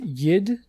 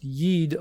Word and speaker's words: Yid: I've heard it's pronounced yid to rhyme with did. That's actually the kind of offensive version mm-hmm. that a non-Yiddish person would use. It's more properly pronounced yid, although even that used by, you know, outsiders is not exactly welcome yeah.